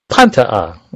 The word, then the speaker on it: Pantea: Pantea. – I forgot see, this is why we have a taped show. Pantea. Accent on the first syllable.